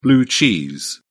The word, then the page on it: blue cheese: British English